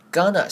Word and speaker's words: gonna: Gonna 是 going to 的连音，我们会念成 gonna.